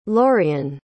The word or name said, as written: lorean